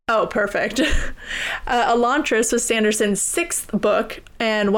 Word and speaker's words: Elantris: I say Elantris.